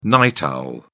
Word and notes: night owl: British English

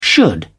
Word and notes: should: British English

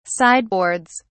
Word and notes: sideboards: British English

